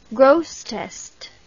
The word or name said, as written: Grosseteste